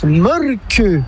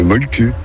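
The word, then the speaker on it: Mölkky: Inventé en Finlande en 1996, ce jeu de quilles s'écrit M-O-T-R-E-M-A-L-K-K-Y. – Ça s'écrit Molki, mais ça se prononce Molke. – Le Molke. – Plutôt un E qu'un E, mais pas un U. Donc ça fait Molke.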